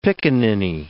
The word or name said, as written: picaninny